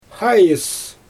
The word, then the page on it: xaajɯs: Xyzyl